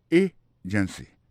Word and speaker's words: agency: Pour mot agency, hein, accent tombé sous premier syllabe là. Agency. Deux autres syllabes qui sont derrière, au faible.